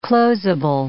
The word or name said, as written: closeable